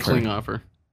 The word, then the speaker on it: Klinghoffer: I'm saying Klinghoffer. – Klinghoffer.